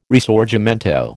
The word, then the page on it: Risorgimento: American English